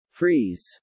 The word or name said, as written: freeze